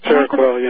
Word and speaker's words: Seroquel: And when the lady said he was on Seroquel, I believe I'm pronouncing that right. – Seroquel, yeah.